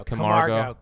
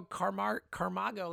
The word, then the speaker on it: Camargo: Like, by the end of the season, Phillies fans' favorite player is going to be Camargo. Like, he's going to be the guy that everyone. – Now, has. Have you got the name yet? – Camargo, right? It's Camargo.